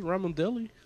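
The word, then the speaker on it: Ramondelli: I'm like, is it Ramadelli? I don't want to say his name wrong. Hold on. – Right. Yeah, it's Ramadelli. – That's how you say it? – Yeah.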